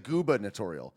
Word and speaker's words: gubernatorial: You got to overpronounce the goober, because a lot of people say gooba. They say like gubernatorial. Gubernatorial.